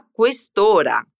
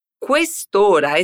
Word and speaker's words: quest'ora: Questa é também um adjetivo demonstrativo, que neste caso pronunciamos junto com a palavra ora. Isso porque temos um encontro entre as vogais, do final de uma e o começo da outra. Quest'ora. Repete comigo. Quest'ora.